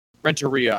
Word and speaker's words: Renteria: Renteria, I believe. – Yeah, I realize now that this is baseball. I have to switch my pronunciation over from fucking like French to Spanish.